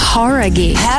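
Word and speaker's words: Jauregui: Horaghi. Não é? – Horaghi. – Horaghi. – Ah, então a gente tava falando errado, cara.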